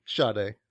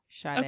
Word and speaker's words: Sade: How do you pronounce it? – Sade. – Sade. Okay.